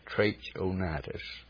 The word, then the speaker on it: Trachonitis: I think that's the way we would pronounce that, Trachonitis, and Lysanias, the tetrarch of Abilene, Annas and Caiaphas.